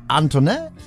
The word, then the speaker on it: Antoinette: Unter anderem DJ Antoinette, so leicht französisch wird das ausgesprochen.